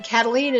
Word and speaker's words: Kataline: Karen is spelled the normal way, no goofy Y's or Q's, K-A-R-E-N, and Cataline is spelled K-A-T-A-L-I-N-E.